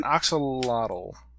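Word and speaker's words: axolotl: It's an oxalotl.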